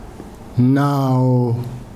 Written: Nawo

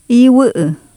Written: iwU7U

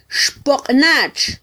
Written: xpoqnatx

